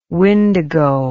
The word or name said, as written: windigo